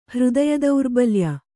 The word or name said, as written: ಹೃದಯದೌರ್ಬಲ್ಯ